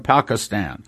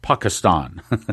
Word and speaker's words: Pakistan: Try doing it in Pakistan. By the way, I'm pronouncing that wrong. He corrected me. It's Pakistan.